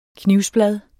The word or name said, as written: knivblad